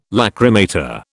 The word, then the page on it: lachrymator: British English